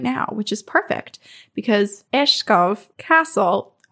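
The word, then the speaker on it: Egeskov: So I am going to begin us with telling you about a place called Igaskov Castle, which is the way that I butcher it as an English speaker. But I believe after listening to about 100 videos of Danish people pronouncing it, it's called Eshkov Castle.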